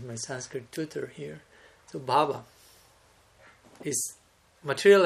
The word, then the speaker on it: bhava: Short. Yeah. Short A. Bhava. I'm doing it long there? – Yeah. – Bhava. – Bhava. – Bhava. – Yeah. – Okay. I remember the correction from last year. I actually want to make that point. Because bhava with long A, bhava, refers to ecstatic emotion, and non-ecstatic emotion will be shorter than that.